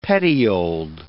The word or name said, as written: petioled